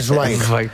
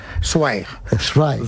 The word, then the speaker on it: Zweig: Por el camino bien, ahora hay que decir Zweig. – Zweig. – Stefan Zweig. – Es así como se pronuncia en alemán.